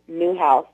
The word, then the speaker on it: Newhouse: It's T-A-M-I-K-A. And last name is Newhouse, spelled like it sounds, N-E-W-H-O-U-S-E.